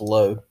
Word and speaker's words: Laux: So, with that being said, Sheriff Lau decided to start with a vicious, condescending interrogation of... – I think it's pronounced Low. Sorry. – Low? – Yeah, it's like a Cajun name. L-A-U-X. Isn't that Low?